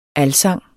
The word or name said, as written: alsang